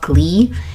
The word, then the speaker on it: Ghlee: There's this one company called Shop Glee, and so it's spelled G-H-L-E-E, so it's ghee based skincare.